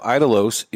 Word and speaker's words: Idolos: The pronunciation of Idolos is with E if you're saying it in Spanish. Mm. If you're saying it in English, it doesn't get the E.